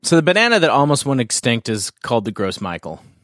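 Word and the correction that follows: Gros Michel: From here, that looks like Gross Michael. – It sounds French or something. – Yeah. – Gross Michel. – Gross Michel.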